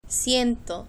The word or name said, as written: ciento